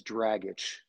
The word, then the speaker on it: Dragich: I just went to Ped, and the last name is Dragic, just like you drag your foot and you itch.